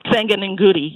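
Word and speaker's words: Senga Nengudi: I wonder, something about being an art historian and approaching performance always seems so difficult to me because, for instance, I'm not sure if I'm pronouncing her name right, but Sanga Ngundi, is that? – Sanga Ngundi, yeah.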